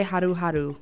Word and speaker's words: Haru Haru: Or how do you say it? – I just say Haru Haru.